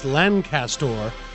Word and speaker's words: Lancaster: It's Lancaster.